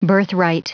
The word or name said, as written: birthright